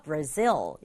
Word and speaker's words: Brazil: 브라질이라는 나라인데 발음이 비슷한 것 같지만 영어는 끝부분에 강조를 줘서 Brazil 이렇게 발음하면 좋겠고요.